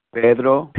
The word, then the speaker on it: Pedro: Pedro. – Pedro. – P-E-D-R-O.